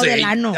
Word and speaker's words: Delano: Pero se dice Delano, ¿no? – Ah, yo no sé. – No, Delano.